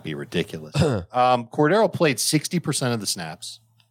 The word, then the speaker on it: Cordarrelle: So if you think he's going to keep making these explosive plays, then by all means, start Cordarrelle, enjoy Cordarrelle.